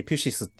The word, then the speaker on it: ピュシス: けど、音楽と生命の中で、その坂本龍一と福岡伸一は、えっ、ー、と、ピュシスに帰りたいっていう話をずっとしてるんですよね。で、ピュシスっていうのは、えっ、ー、と、すごい概念的な言葉で、自然と書いてピュシスって読むんですよ。